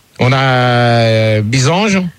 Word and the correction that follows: Byzance: Oui, c'est Bisance. – Ah, Byzance, pardon, excuse-moi de d'écorcher ton nom.